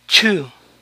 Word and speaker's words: chew: Masticar se pronuncia chu, chu. Entonces la EW final en estas palabras suena u.